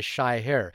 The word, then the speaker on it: Shahir: For some reason, when I grew up in Fiji, and when I moved to New Zealand, people couldn't pronounce my name as Shyher. And I always say it's just the word shy and then her. But for some reason, they would always call it Sha instead of Shy and here instead of her.